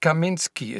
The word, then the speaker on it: Kaminski: Heinz Kaminski ist mein Name. Das schreibt man K-A-M-I-N-S-K-I.